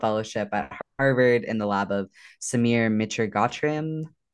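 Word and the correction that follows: Mitragotri: Mitragotry, yeah.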